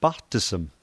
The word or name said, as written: paqtism